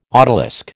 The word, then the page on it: odalisque: American English